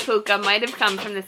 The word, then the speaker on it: pooka: So, the meaning of puka, which is, it's spelled P-O-O-K-A, and it is pronounced exactly like it's spelled, is puka.